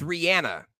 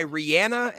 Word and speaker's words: Rihanna: I said the name wrong. It's Rihanna. Okay. I've seen in an interview that she goes by Rihanna and not Rihanna.